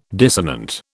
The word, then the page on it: dissonant: British English